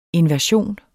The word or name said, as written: inversion